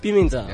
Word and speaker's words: Pimentel: So my man, Seth Pimentel. Am I saying that correctly? – It's actually Pimentel. – Pimentel. – Yeah, it's weird. It's Portuguese.